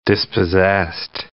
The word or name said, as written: dispossessed